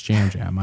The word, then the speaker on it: Yam Yam: Even though his name is spelled Yam Yam, it's Jam Jam. I was corrected on it. I've learned my lesson.